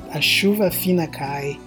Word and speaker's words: a chuva fina cai: Yeah, it was one of the first things I learned how to say, actually, and it's a chuva fina cai, and apparently my pronunciation is somewhat decent, which is comforting to me. – Yeah, it's perfect. A chuva fina cai.